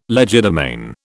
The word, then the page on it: legerdemain: British English